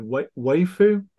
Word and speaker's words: waifu: Waifu? – A waifu, yeah.